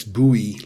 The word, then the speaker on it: Bowie: Kurt Flood was a very good Major League Baseball player, and Bowie Kuhn, and that's Bowie, like the Bowie knife, and Kuhn, but it's spelled K-U-H-N.